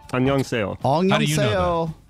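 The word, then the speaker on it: Annyeonghaseyo (안녕하세요): Annyeonghaseyo.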